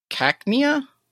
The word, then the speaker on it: Cacnea: November 2nd is Cacnea. I said it right. All right. – You said it right. Good job.